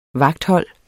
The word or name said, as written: vagthold